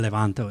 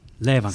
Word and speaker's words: Levanto: or where Levanto is. Levanto. I'm going to say it right. Levanto. – Sorry about that. – No, I said it wrong.